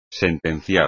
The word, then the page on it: sentenciar: espagnol